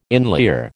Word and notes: inlier: American English

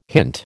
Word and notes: hint: American English

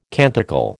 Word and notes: canticle: American English

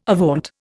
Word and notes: avaunt: Indian

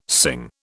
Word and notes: sing: British English

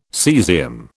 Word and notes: cesium: British English

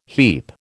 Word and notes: heap: American English

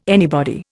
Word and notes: anybody: Indian

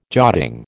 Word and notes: jotting: American English

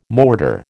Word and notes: mortar: American English

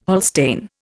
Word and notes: Holstein: Indian

